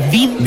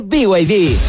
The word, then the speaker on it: BYD: ¿Los VID? – ¿VID? ¿No es BYD? – Nada que ver. Se escribe BYD, pero se dice BYD.